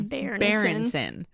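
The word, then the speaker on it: Byrnison: I can't forget to pronounce the bear in that name. – Berenson.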